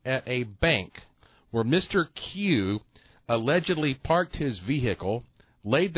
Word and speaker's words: Kieu: His first name is P-H-U-C. And his last name is K-I-E-U. – How's that pronounced, that K-I-E-U? – He is Mr. Q.